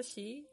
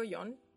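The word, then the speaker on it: shi (四): Pero en japonés, en japonés por lo menos el 4 se pronuncia shi, y como que, o sea, se puede decir shi o yon, pero sí es como de morir, así como chino.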